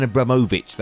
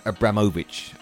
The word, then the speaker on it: Abramovich: Time to check in now with your weekend breakfast show and big Tony Cascarino discussing Roman Abramovich. That's how you genuinely pronounce it, not Roman Abramovich. It's Roman Abramovich.